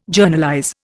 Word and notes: Journalize: Indian

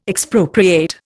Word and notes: Expropriate: Indian

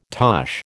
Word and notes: tosh: American English

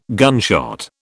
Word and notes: gunshot: British English

